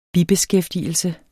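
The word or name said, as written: bibeskæftigelse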